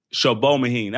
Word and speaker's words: Sobomehin: Uh, so, Alani, you, you gotta you gotta correct me if I get your name wrong, but um, Alani Sobenhin, Sobenhin, Sho Shobomahin.